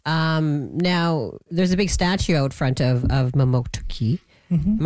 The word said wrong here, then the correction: Mamotokati: Am I saying that right? Mamotoki? – No, Mamotokati. – Mamotokati. Oh, I missed something in there.